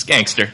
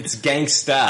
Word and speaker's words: gangsta: It's gangster. – It's gangsta. – Oh, sorry. There's a difference.